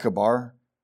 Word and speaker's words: Chebar: The proper way, I'll just tell you right now, you're supposed to pronounce that Kibar, but I've never done it. Kibar, if you want. We're not Jewish. I'm just talking about in English. If we want to pronounce it properly, it's like a K sound, not a Ch.